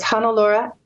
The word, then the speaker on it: Hannelore: My name is spelled H-A-N-N-E-L-O-R-E. So it's Hannalaura at hannalaura.ca is my email address.